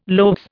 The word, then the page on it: loaves: Indian